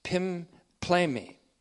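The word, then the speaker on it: pimplemi (πίμπλημι): So when a person or persons, when a single individual, human being, or a group of human beings is said to be pimplemi, P-I-M-P-L, long E-M-I,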